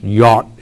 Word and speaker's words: yod: If you have your Bible, you'll have that J-O-D, which is pronounced yot in the Hebrew.